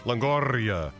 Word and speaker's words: Longoria: Longoria.